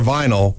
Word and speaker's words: vinyl: Remember, for fencing, DLR vinyl rhymes with final.